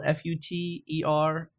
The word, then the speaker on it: Futernick: Futernick is spelled Futernick.